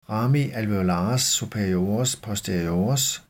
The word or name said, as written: rami alveolares superiores posteriores